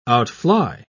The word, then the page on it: outfly: British English